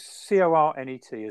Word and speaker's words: Cornet: It's C O R N E T, isn't it? So yep. I think I'm going to pronounce it Cornet.